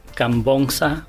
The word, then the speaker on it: Khamvongsa: Miguel Praset Cambonza Bernal.